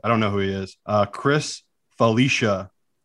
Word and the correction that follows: Felica: Felica. – Chris Felica.